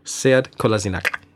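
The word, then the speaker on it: Sead Kolašinac: Said Kolasinac, if I pronounced that right. – Said Kolasinac. – Oh, there we go. – Bosnian. But only because I have to say his name every single week, and I've asked him what the Bosnian pronunciation is.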